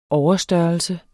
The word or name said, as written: overstørrelse